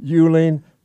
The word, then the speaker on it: Uehling: U-E-H-L-I-N-G. Thank you very much, Kevin. Okay, it's not an easy name to spell. Ed Euling for Lieutenant Governor, U-E-H-L-I-N-G.